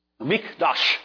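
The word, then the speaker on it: Mikdash (מקדש): Mishdash. Mikdash. Mikdash. Don't you know how it's spelled? A mem, a kaf, a dalet, and a shin. Mikdash. Mikdash. Mikdash, rather. What does that mean?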